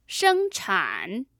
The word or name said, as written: shengchan